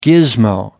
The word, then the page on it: gizmo: American English